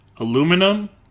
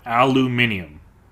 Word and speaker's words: aluminium: Do you pronounce it aluminum or aluminium? – Aluminium, 100%.